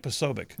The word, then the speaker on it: Posobiec: Jack Posobiec.